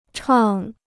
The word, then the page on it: 唱: chàng